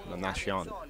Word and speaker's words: La Nazione: And in English, that would spell Nazi 1. The way it's spelled, I never realized that before. It's La Nazi 1, but obviously in Italian it's pronounced differently. La Nazione.